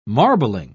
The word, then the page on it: marbling: British English